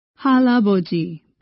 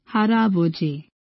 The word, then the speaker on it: 할아버지: Let's practice R sound with 할아버지, grandfather. In English, 할아버지, but in Korean, 할아버지. Use an R sound.